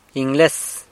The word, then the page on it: Ingles: sanlucasquiavini